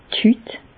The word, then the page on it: d' Hüt: Bas-Rhin; Reichshoffen